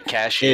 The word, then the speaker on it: Cassian: But Cassian. – Cashin, is, baby. – Yeah, yeah, Cashin. – Spell it. Spell it. – C-A-S-S-I-A-N.